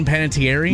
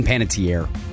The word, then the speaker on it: Panettiere: Am I pronouncing her name right? Hayden Panettiere? – No, it's Hayden Panettiere. – Tier. Dang it.